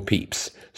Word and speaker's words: Pepys: And the author, who presumably would be up on all the correct pronunciation, pronounces it Samuel Pepys.